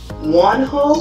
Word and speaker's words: Wonho: The one that I just watched not too long ago was Wonho, is that how you pronounce his name? – Mm-hmm.